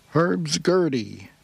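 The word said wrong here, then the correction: Hurdy Gurdy Glissando: Did I say that right? No, Herdy Gertie Glissando.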